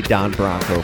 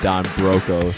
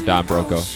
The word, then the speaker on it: Don Broco: And he would, yeah, it would be Don Bronco for him. – She said she was calling him Don Broco, so... Or, Broco. Broco, that's what it is. They are Don Broco.